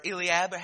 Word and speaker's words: Eliab: It says in verse 6, "And it came to pass when they were come and looked on Eliab," or Eliab, however you would want to pronounce it, pronunciations both way, Eliab, "and said, surely the Lord anointed is before him.